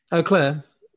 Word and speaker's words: Eau Claire: Yeah, because there's so many weird pronunciations of it, like U and O, and instead of Eau Claire, because they don't know it's a French for clear water.